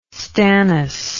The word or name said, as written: stannous